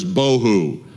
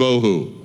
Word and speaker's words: bohu: Two words in Hebrew that are easy to remember by pronunciation. One is tohu, the other is bohu. Tohu and bohu.